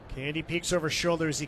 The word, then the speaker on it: Cande: So this is the 5th year for Candy as a starter, as a pro. His last name, by the way, is spelled C-A-N-D-E, pronounced like cotton candy.